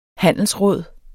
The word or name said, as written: handelsråd